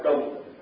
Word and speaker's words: trùng: Cái chữ này chữ tạm nhưng mà phải đọc là trùng.